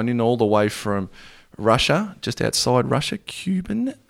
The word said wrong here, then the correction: Kuban: Kuban, thank you.